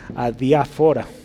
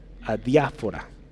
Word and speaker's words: adiáfora: adiófara. otra vez. Adiáfora. Adiáfora. Este, hay un acento en la adiáfora.